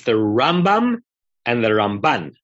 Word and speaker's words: Rambam: Yes, but first you have to pronounce it the way Shlomo Riskin tells us to. Rambam.